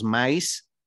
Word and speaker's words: Mays: Eh, el primero es Volando con los Mais. Mais se escucha, digo, se escribe M-A-Y-S